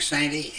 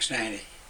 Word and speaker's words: Santy: Frank Sandy, S-A-N-T-Y. Frank Sandy.